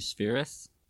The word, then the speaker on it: Spheeris: Penelope Spheeris? – That's how you say it.